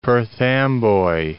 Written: Perth Amboy

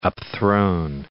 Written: upthrown

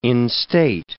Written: instate

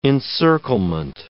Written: encirclement

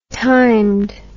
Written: timed

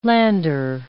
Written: lander

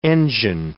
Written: engine

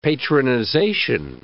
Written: patronization

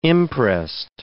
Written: imprest

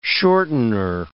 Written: shortener

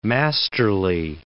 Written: masterly

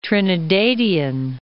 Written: Trinidadian

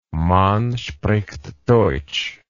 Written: man spricht deutsch

